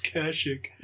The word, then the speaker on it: Kashyyyk: I'm saying it wrong. It's Kashyyyk.